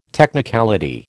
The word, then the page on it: technicality: American English